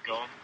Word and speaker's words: Gon: I'd say Gon. With a Japanese accent, you know. – I say John. – John. – Gone.